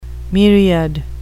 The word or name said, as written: myriad